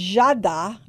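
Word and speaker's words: Giada: It's Jada. That's how you say her name.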